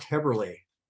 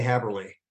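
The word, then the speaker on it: Häberli: It's actually pronounced Haberli. – Haberli, excuse me. – Yeah. – I've been mispronouncing your name all these years. – When I left home many years ago, everybody mispronounced it. And I thought, I'm just going to say Haberli because that's the way it's spelled.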